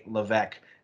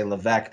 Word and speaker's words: Levesque: Levesque. And the S is silent. I know I say Levesque. There's an S in there. I know that's confusing. It's silent.